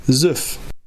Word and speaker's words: Syff: But I laughed a little bit when I said the name there a moment ago, and that's because I had to ask the band how to pronounce it. Because like I said, it's spelled S-Y-F-F. And I couldn't help but laugh because this is, this is the audio file that was sent to me.